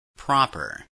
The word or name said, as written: proper